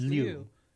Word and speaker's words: Liu: I never pronounce his name right. I should just use the American pronunciation. It's Ken Liu in American, but it's spelled L-I-U. So it's really... – I've had Chinese students for years. It's Liu.